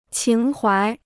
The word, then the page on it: 情怀: qíng huái